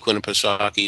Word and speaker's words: Winnipesaukee: So coming back over the pond, Peter went north to the, let me try this, Winnipesaukee Playhouse? Winnipesaukee? – Yeah, Lake Winnipeg. If you were from New England, you'd have no problem pronouncing that word because Lake Winnipesaukee is a big vacation area.